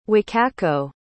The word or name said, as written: wakako